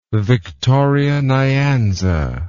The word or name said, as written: Victoria Nyanza